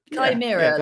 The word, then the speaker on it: Chimera: Chimera. like the mythical creature.